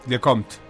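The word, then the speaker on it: Lecomte: La mejor de la temporada, que es la francesa Loana Lacomte. O no se dicen la, palabra, la, sílaba final en francés. Le Comte. Le Comte.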